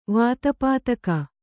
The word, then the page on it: ವಾತಪಾತಕ: Kannada